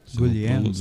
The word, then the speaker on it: Guglielmo: Guglielmo. Você não pronuncia o G. – Olha. – No italiano, a pessoa tem mania de pronunciar o G.